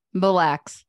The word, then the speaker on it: Mille Lacs: Lac Mills. Is that how you say it? – Mille Lacs. Other way around.